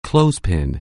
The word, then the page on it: clothespin: anglais